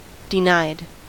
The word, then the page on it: denied: American English